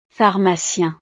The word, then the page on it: pharmacien: frances